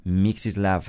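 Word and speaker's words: Mieczysław: Se trata de, pronunciación compleja, pero podríamos llamarlo Moisés Weinberg. Mixislav Weinberg, si queréis.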